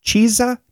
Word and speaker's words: chizza: Chizza? Chizza. I would say chizza, like pizza.